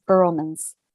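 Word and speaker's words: Vroman's: It's spelled V-R-O-M-A-N apostrophe S, Burlman's.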